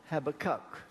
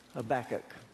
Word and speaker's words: Habakkuk: Now, you that don't know better, call it Habakkuk. But I had a word with Habakkuk. He told me he pronounces his name Habakkuk.